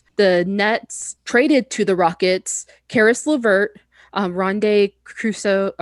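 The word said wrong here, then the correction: Rodions Kurucs: I always say his name wrong. – Rodion's Kurux.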